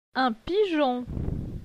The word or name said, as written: Un pigeon